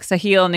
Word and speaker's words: Sahil: So for me, it sounds I'm like Sahil, Nikhil.